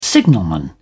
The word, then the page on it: signalman: British English